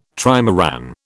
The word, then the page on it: trimaran: British English